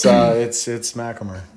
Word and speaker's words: MacHamer: Yeah, no, it's MacHamer.